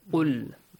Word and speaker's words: قُلْ: The first important point is the letter Qaf. Make sure you pronounce it correctly. قُلْ. – Yes. – Not قُلْ. قُلْ, actually, when it has a Dhamma, it's not much difficult. When it has a Fatha, it's a bit difficult. Sometimes we pronounce it with we mix it up, okay, with light letters, while it is a heavy letter.